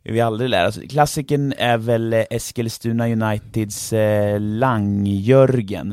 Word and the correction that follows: Langörgen: Nej? – Langörgen. – Langörgen, det korrekta.